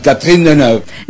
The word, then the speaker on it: Catherine Deneuve: Y yo la verdad que sentí que estaba con Catherine Deneuve. – ¿Qué pasó? Pero qué bien lo pronuncia.